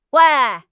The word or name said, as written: wei2